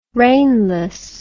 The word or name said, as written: rainless